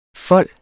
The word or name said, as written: folde